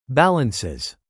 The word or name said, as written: balances